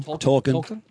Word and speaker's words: Tolkien: Tolkien? – Tolkien.